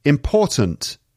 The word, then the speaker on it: important: Okay, next one, important. Important. And again, the second syllable is stressed. Important. Sometimes the T at the end gets cut.